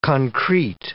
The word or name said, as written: concrete